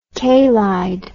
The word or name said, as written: kaylied